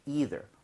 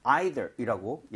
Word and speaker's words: either: 너도 마찬가지로 아니다라고 얘기를 할 때 부정을 쓸 때는 우리가 either 혹은 발음할 때 사람에 따라 either이라고 얘기를 하게 되는데 예를 들어 이거 한번 해볼까요?